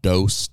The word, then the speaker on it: Dose: They have a group called Dose, D-O-S-E, Hermanos. And it is it's amazing. – Holy shit. – These two. – You said it right.